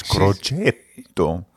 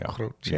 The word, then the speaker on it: Crocetto: So it's two T's. Crocetto. – Okay. – Crocetto. – There you go. – Yeah. Crocetto. You got it.